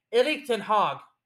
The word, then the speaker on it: Erik ten Hag: Erik ten Hag, which, by the way, now I'm finally saying it correctly,